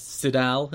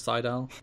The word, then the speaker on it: Sydal: And Mike Tenay pronounces it as Sidal instead of Seidel.